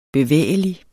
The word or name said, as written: bevægelig